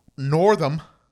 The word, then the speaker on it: Northam: His name is Governor Ralph Northam. I guess that's how you say it. – Yeah, sounds right.